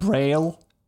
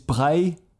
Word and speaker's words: Braille: It is not Braille. It is Braille, because the inventor of Braille is Louis Braille, which was French.